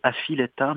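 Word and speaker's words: A Filetta: Ça s'écrit Avileta, mais en Corse, on prononce Avileta. – OK. Le F se prononce V.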